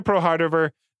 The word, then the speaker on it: Prohardver: Anyway, so according to ProHardver, which is spelled in a Hungarian way, so that's how I'm pronouncing it.